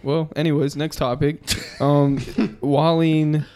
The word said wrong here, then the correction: Colleen: I tried to, like, Colleen.